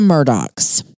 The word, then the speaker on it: Murdaugh: Well, first of all, if you notice, it's spelled M-U-R-D-A-U-G-H. And you're like, which is confusing. – You're saying that wrong, but it is pronounced Murdaugh, despite the spelling.